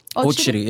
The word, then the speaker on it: Okyere: In short, Sam Okyere. It's my business name. – We've been saying it wrong the whole time. – How do you pronounce your last Okyere. – Name? – Okyere, yeah.